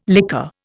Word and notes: liqueur: Indian

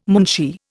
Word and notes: moonshee: Indian

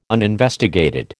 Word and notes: uninvestigated: American English

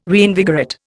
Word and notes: reinvigorate: Indian

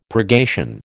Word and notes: purgation: American English

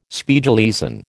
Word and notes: Spiegeleisen: American English

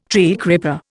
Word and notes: treecreeper: Indian

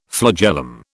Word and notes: flagellum: British English

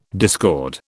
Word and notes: discord: British English

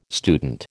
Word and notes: student: American English

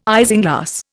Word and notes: isinglass: Indian

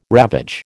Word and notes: ravage: American English

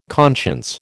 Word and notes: conscience: American English

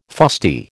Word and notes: fusty: American English

